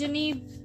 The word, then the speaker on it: Eugenie: It's Eugenie, but it's spelled Eugenia. – I am going to call her Eugenia because it makes more sense to me.